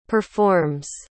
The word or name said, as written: performs